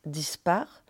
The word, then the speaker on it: dispac: Et dispac, qu'on prononce dispar, ça veut dire révolte, révolution.